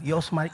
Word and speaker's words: Iosmar: I know it's me because no one can say my name. Yosmar, Ishmar, Ashmar, Oshmar.